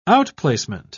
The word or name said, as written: outplacement